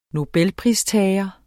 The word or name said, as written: nobelpristager